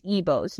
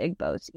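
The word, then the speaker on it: Igbo: The Igbos, I'm sorry, I think I pronounced it wrong in the beginning. It's Igbos, not Igbos.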